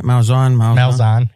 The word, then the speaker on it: Malzahn: So Malzahn, is that how you say it? Malzahn, yep.